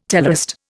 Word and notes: teleost: Indian

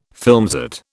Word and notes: filmset: British English

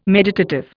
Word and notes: meditative: Indian

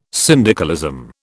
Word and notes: syndicalism: British English

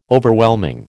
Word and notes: Overwhelming: American English

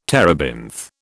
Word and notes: terebinth: British English

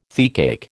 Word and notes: teacake: American English

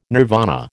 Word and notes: nirvana: American English

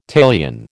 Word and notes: talion: American English

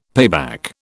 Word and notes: payback: British English

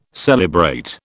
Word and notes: celebrate: British English

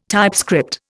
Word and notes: typescript: Indian

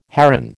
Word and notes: heron: American English

